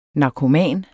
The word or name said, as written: narkoman